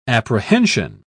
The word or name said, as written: apprehension